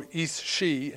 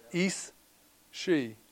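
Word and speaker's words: aos sí: This meant the spirits or fairies, or Ís-Sí in Gaelic, and that's another one that's spelled nothing like it sounds, A-O-S-S-I. Is A-O-S, Ís-Sí.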